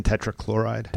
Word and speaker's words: tetrachloride: Carbon tetrachloride.